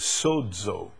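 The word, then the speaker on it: sozo (σῴζω): And you know, the word salvation, the Greek word is sozo. Our English translates S-O-Z-O. It's pronounced with a D, sozo.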